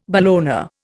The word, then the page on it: bologna: Indian